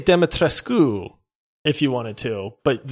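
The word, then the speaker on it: Dimitrescu: But she was from Romania, and so she actually pronounced the U. Now, she said it with a heavy accent, but she would say, oh, yes, Lady Demetrescu.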